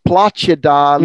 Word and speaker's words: placida: So you pronounce that C-I as placida, lucida.